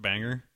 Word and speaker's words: Bangor: And I believe it's pronounced Banger.